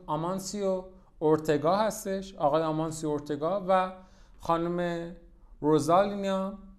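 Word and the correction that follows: Rosalía: روزالیا ببخشید روزالیا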